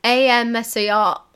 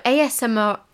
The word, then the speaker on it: ASMR: ASMR.